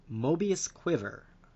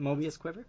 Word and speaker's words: Mobius Quiver: Moving on to Night Stalker, we have, oh wow, I'm going to butcher this one, Mobius Quiver. – That's it. – Was it Mobius Quiver? I got it.